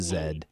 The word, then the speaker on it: Z: I do not pronounce that letter that way. I am a Canadian. It's pronounced Zed.